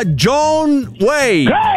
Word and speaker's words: John Wayne: Allora si pronuncia John Wayne.